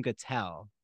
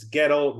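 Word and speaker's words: Guettel: And um, the mother of, I always say Adam Gattel, but I don't know if that's how you pronounce it. No, how do you pronounce it? – Uh, Gettle? As, as, it's as he initially told me when I met him. It's Gettle rhymes with Shtetl.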